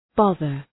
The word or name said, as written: bother